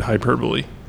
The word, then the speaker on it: hyperbole: He got into a little bit of a kerfuffle with his teacher this week over the word hyperbole. – How did he pronounce it? – Hyperbole. – That's how you pronounce it.